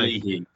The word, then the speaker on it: Leahy: Um, with obviously the wing backs and then Taylor Moore and is it how do you pronounce is it Leahy? – Luke Leahy, yeah.